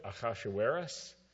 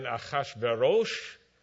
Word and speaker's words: Ahasuerus: Two ways of pronouncing his name in Hebrew, Ahasuerus. Can you say that? That's how I learned it growing up. And then Ahasverosh is another way, more modern way of saying it.